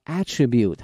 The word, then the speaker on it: attribute: 那么作为名词的话哈、啊，念成了 attribute 哈、啊，它的意思呢指一个东西特有的一个属性哈、啊，用这个词 attribute。